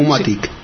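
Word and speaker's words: Foomatic: Sí, pero no, sí, es INTE, F-O-M. – F-O-O. – O-O-M-A-T, vale. Y latina. – Vale, C. – Pero F-O-O-F-O no se dice FO, se dice FU. – Se dice FU, o sea. Entonces, que Fumatic.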